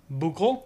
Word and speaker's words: Bukel: Bukel? – Yeah, spelled like buckle. – I like that. – Bukel.